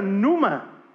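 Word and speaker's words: numa (πνεῦμα): Y en griego eso se pronuncia numa. Numa.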